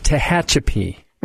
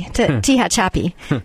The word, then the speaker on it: Tehachapi: The city is called Tehachapi.